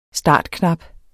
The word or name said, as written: startknap